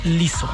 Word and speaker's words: Lizzo: Es L-I-Z-Z o Liso.